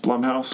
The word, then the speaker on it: Blumhouse: I think it's pronounced Blumhouse. – Blumhouse, yeah. My bad.